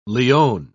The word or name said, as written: leone